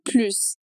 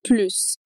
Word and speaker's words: plus: Uma explicação bem simples sobre o assunto, que pode te ajudar, é que quando plus significa mais, a gente vai pronunciar o S, então plus, como na nossa frase.